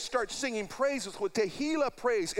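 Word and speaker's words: tehillah (תהילה): No, the word there, praise, is the word tehillah. Tehillah. T-E-H-I-L-L-A-H. Tehillah. You want to try to remember how to say it, just think of tequila.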